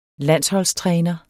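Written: landsholdstræner

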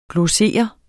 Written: glossere

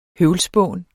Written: høvlspån

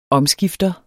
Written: omskifter

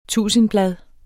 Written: tusindblad

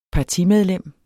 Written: partimedlem

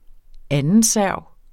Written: andenserv

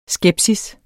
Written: skepsis

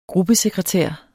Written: gruppesekretær

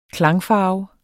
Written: klangfarve

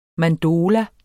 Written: mandola

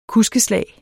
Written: kuskeslag

